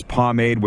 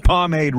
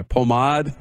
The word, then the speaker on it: pomade: From the second I used this pomade, which I didn't know how to say it. What's this? Pomade? No, it's pomade. – Yeah, pomade.